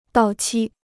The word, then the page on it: 到期: dào qī